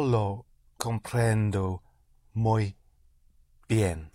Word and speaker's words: comprendo: Comprendo. Always on the syllable before last, on the penultimate syllable. If there are only two syllables, you push down on the first. Otherwise, it's always on the penultimate syllable.